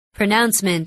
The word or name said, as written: pronouncement